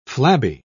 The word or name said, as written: flabby